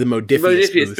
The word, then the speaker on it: Modiphius: And then I'll probably be over at the Mobius booth. Did I say that right? – If you're talking about VR, that would be the Modiphius booth.